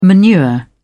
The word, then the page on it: manure: British English